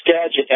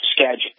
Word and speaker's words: Skagit: It's Skagit as a J, but it's spelled with a G. Skagit. – Skagit, thank you.